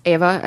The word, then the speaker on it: Ewa: One is Ava, as we said, EWA. W's are pronounced like V's, as my husband tells me.